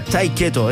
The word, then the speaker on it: Tiqueto: Esto es un grupo que se pronuncia Tiqueto, es como Tate Quieto.